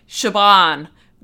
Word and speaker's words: Siobhan: Uh, I say weird, but like Shaban,